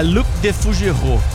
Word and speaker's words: Luc Defougerot: Mas como é a pronúncia aí, ô Léo? – É Luc Defougerot.